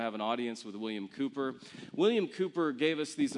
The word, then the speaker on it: Cowper: And he said, there in that little town is the Cooper Newton Museum. Now, it looks to us Americans like Cowper, C-O-W-P-E-R, but we have to pronounce it like a good Brit, so Cooper, the W is silent.